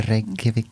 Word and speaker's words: Reykjavik: The Reykjavik Brewing Company.